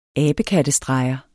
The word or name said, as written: ABEKATTESTREGERNES